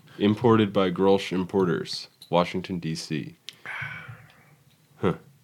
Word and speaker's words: Grolsch: How do you like pronounce that? Grolsch? – Grolsch.